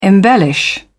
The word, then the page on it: embellish: British English